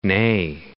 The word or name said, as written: Neagh, Lough